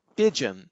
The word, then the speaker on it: Pyjion: and he picked up a project named Pigeon. I think it's spelled P-Y-J-I-O-N, which is a JIT compiler for Python based on the .NET framework.